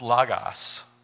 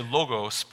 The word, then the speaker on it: logos: Sometimes people read it and they say logos, but it's not logos, it's logos. Greek has two different letters that we consider O's. And so you have omicron, which is a short O, and the omega, which is the long O. These are the omicrons, so it's logos.